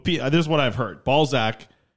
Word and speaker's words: Balzac: B-A-L-Z-A-C. Balzac.